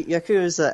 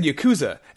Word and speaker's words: Yakuza: No, but you said Yakuza, and I also say Yakuza, but people will be like, "No, it's Yakuza." – It's Yakuza because it's not. You're not supposed to pronounce the U.